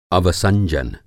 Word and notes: अवसंजन: Hindi

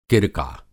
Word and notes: किरका: Hindi

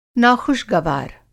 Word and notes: नाख़ुश-गवार: Hindi

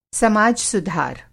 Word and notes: समाज-सुधार: Hindi

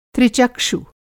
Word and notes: त्रिचक्षु: Hindi

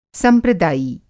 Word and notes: संप्रदायी: Hindi

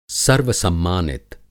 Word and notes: सर्वसम्मानित: Hindi

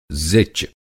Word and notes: ज़िच: Hindi

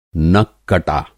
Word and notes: नककटा: नक-कटा

